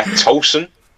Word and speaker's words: Tosin: I think Tosin, or Tosin, I keep getting his name wrong, it bothers Arteta, drives him crazy that I call him Tosin sometimes and not Tosin. Yeah, Tosin.